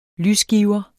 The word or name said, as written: lysgiver